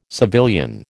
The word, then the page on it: civilian: American English